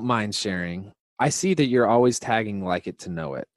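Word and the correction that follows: LIKEtoKNOW.it: Did I say that right? – Yeah, Like to Know It. – Like to Know It. I always add the it.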